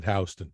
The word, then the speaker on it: Houston: It's spelled Houston, and the locals call it Houston.